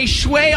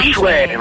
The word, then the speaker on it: feng shui: It's pronounced feng shui. Feng shui. – Feng shui. See, thanks, so, buddy. That's exactly what I needed this morning. – So apparently you're supposed to say shui.